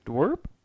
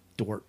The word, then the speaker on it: dorp: Dwerp. – Dwerp. – Yeah. A small rural town or village. Often used to suggest a place that is backwards or unimpressive. – How do you spell that? – D-O-R-P.